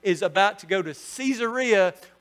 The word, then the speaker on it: Caesarea: There are two Caesareas in the Bible. And I pronounce it Caesarea because they're named after Caesar Augustus.